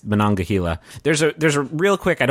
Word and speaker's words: Monongahela: So the game is going to take place in a fictional ski town on the eastern edge of West Virginia, in the Monongahela National Forest. By the way, did you guys know it's pronounced like that and not Monongalia? – I thought it was Monongalia.